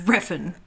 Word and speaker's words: Refn: You are so close. You're almost there. – Refn. – Nicholas Winding Refn. – Refn. – Refn. – It's an E. Refn.